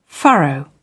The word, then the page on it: furrow: British English